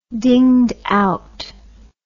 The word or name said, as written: dinged out